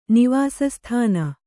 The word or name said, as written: ನಿವಾಸಸ್ಥಾನ